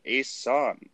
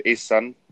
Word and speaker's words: Asan: Is it Asan? – You said it perfectly, bro. You said it Asan? – perfectly. Like, A-san, yeah, that's it, man. A-san.